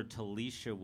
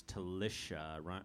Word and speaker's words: Telisha: They are playing tomorrow, Doug, and a lot of people call her Telisha Williams, but it's Telisha, rhymes with delicious.